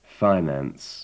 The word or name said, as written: finance